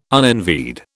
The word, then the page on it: unenvied: British English